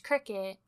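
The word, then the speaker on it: Cricut: And basically, my entire family went in on a cry cut machine. And you can tell me it's pronounced cricket all you want. I refuse to call it that.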